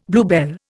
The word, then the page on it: bluebell: Indian